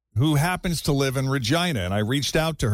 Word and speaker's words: Regina: It's spelled Regina, but you pronounce it Regina.